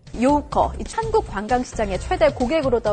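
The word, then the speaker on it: 요커 (游客): 한자로 놀러 다닐 유, 손님 객자인데요. 중국말로 요커라고 읽고 관광객을 뜻합니다.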